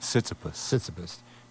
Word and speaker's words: Tsitsipas: Tsitsipas, who is 22.